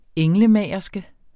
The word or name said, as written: englemagerske